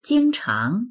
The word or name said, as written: jing1chang2